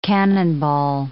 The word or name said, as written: cannonball